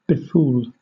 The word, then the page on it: befool: British English